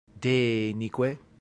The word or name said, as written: denique